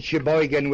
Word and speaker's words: Sheboygan: I attended college and seminary outside of a city called Sheboygan, Wisconsin. Sheboygan is spelled S-H-E-B-O-Y-G-A-N.